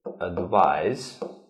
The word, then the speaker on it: advise: Advise, it means to give advice. And this is a device. Noun, it is a noun, and this is a verb. So we write them and pronounce them differently.